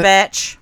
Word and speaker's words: Bach: Burger Batch. – Yeah, and spelled Bach, but pronounced Batch.